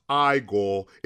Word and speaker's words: Igor: Actually, it's pronounced IGOR.